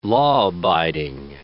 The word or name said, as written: law-abiding